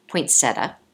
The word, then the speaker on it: poinsettia: So I'm going to let you pronounce it because I do a terrible job. What is the plant? – With my Chicago accent, poinsettia.